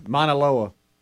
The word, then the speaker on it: Mauna Loa: M-A-U-N-A-L-O-A.